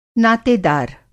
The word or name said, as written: नातेदार